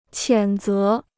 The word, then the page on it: 谴责: qiǎn zé